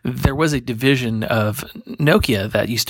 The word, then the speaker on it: Nokia: And Nokia or Nokia, not Nokia, as some people mispronounce it.